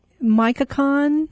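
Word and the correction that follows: Michoacán: I think it's Michoacan.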